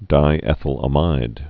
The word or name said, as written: lysergic acid diethylamide